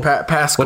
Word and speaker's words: Pascal: Pascal. What's his? It's like Paschal, but it's pronounced Pascal.